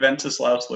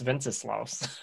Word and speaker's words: Wenceslaus: A good King Wenceslaus looked down on the feast of Stephen. – I learned something today. – I learned how to pronounce Wenceslaus.